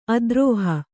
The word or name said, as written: ಅದ್ರೋಹ